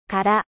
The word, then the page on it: vide: French